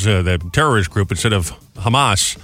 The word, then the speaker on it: Hamas: No, I think he just pronounced it wrong. You know, he changed the emphasis on the A. So instead of hamas, he said, oh, the hummus is bad.